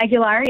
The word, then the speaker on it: Aguilar: Aguilar, yeah.